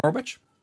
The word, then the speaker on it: Norwich: Did I say that right? Norwich? – Yes. Yep.